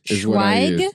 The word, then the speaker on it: Schweig: Now, how are we saying his last name? I've actually never heard his last name pronounced. – Uncas? – Yeah, Eric Schweig. Schweig? Yeah.